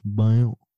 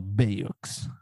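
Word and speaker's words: Bayeux: A-Y-E-U-X. Det känns som att det uttalas bio. – Ja, ja det var, jag tror det är nära faktiskt. Eller biox. – Men det låter som en sån här cool... – E, X uttalas snarare ö.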